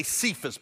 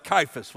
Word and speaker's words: Cephas: You want to say Cephas, but in the original language, it's pronounced Cephas with a K.